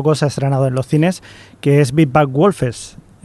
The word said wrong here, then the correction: Wolves: Big, Big, Bad Wolf. – es que esta es complicada. – Wolves.